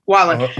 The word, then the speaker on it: Wallen: How do you pronounce his name? Wallen.